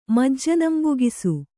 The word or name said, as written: ಮಜ್ಜನಂಬೊಗಿಸು